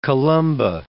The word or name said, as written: Columba